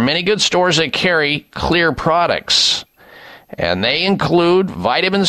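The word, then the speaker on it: Xlear: and other retailers that carry Clear, spelled with an X, X-L-E-A-R, Clear Nasal Spray products.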